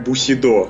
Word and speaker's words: Bushido: Да, потому что Бушидо тоже тоже читается как бусидо.